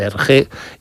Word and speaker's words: Hergé: y utilizó la R de Remy, que allí se pronuncia R de Hergé, y el nombre de George G.